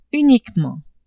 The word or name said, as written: uniquement